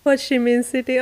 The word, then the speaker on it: Ho Chi Minh City: Ho Chi Minh City, azt hiszem így kell kiejteni. – Ho Chi Minh City, aha.